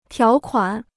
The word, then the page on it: 条款: tiáo kuǎn